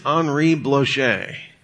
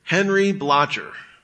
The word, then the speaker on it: Henri Blocher: If he's French, it's Henri Blocher. If he's from Texas, it's Henry Blocher, Bruce Waltke, and Gordon Wenham.